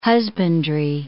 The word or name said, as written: husbandry